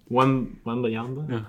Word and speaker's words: Wembanyama: What's that last name? – Wembanyama. – Wembanyama.